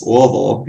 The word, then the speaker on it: Louisville: That's how we pronounce it there. It's not Louisville or Louisville. It's Louisville. You gotta have that southern drawl to it.